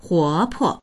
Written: huo2po

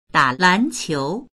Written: da3lan2qiu2

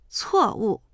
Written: cuo4wu4

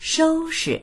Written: shou1shi

